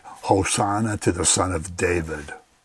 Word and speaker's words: Hosanna: And if you say Hosanna, that means come and save us now. It puts the emphasis on the last syllable, means come and save us now.